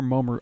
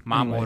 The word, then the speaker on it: Mamoru: Is it Mamoru or Momoru? I always say that wrong. – Mamoru. – Anyway. Mamoru.